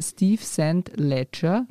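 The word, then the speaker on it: Steve Saint Leger: Regisseurin, und auf der anderen Seite war Steve Sand Ledger. Ja. Spricht man ihn so aus? Richtig, ja, ich glaube, ja.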